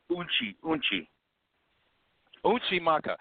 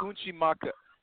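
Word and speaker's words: Unci Maka: And let me know if I'm pronouncing this word right, but I'm going to say, besides Yuntsi Maka, is that how you pronounce Mother Earth? Yuntsi Maka? – Unchi Maka. – So besides Unchi Maka.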